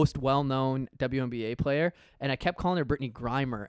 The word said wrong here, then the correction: Griner: and then he's like, you know, it's Griner, right?